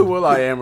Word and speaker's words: will.i.am: Talk to Will.i.am or something. Maybe Will.i.am. – I was about to say Sam.i.am. – Sam.i.am and Will.i.am. – Which is pronounced William. A lot of people don't know that.